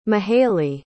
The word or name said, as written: mahalie